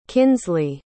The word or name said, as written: kynsley